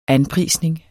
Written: anprisning

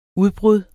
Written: UDBRUDDETS

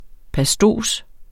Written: pastos

